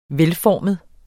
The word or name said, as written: velformet